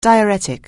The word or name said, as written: diuretic